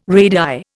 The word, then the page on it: radii: Indian